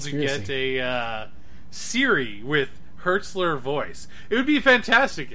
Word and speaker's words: Hertzler: Maybe it was a J.G. Heltzer in... Hertzler? Impersonator. – Hertzler. – Yes, Hertzler. Oh. I've been pronouncing it wrong for ages.